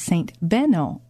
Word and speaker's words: Benno: The confusion turned out to be in the pronunciation, because in reality it was pronounced Saint Benno, a German saint who was one of the patron saints of fishermen. This young man who loved fishing found a friend in Saint Benno, someone in whom he would be able to identify with for life and for eternity.